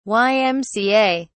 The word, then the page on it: ymca: British English